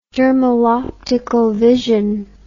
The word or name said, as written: dermal optical vision